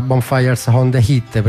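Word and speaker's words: Bonfires on the Heath: Bello Reno del Client Hill, il brano nella pronuncia corretta era Bonfires on the Hit, prima non so che confusione ho fatto.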